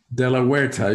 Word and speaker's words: de la Huerta: De La Huerta. You got pretty close. H is silent in Spanish.